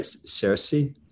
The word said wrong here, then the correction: Saoirse: Saoirse. – Yeah, Saoirse. Yeah.